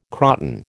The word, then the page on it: croton: American English